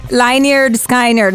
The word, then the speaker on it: Lynyrd Skynyrd: Lynyrd Skynyrd, non lo so se si dice bravissima.